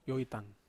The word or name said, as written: oitan